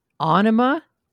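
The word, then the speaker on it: Ænima: Yeah, so I think it's actually pronounced Anima, oh. because it has that weird little... – Yeah, like, I did. Yeah, thing. That one was Anima. Yeah, okay.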